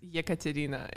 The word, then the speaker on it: Ekaterina: Yes, it's EKATERINA.